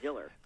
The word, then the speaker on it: Diller: Killer rhymes with Diller.